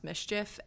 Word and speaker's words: MSCHF: So Nike has sued, I think it's pronounced Mischief, M-S-C-H-F, the Brooklyn-based brand because they used a Nike shoe and then sold it without Nike authorization.